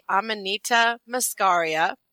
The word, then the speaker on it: Amanita muscaria: It's called, I had to Google how to say it correctly, Amanita muscaria.